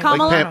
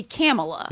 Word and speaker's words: Kamala: It's like Pamela. – I pronounce my name. – Kamala. – Like Pamela. – Like Pamela. – So would it be Kamala? – Yeah. – Okay, like camel toe. – Or like Camelot.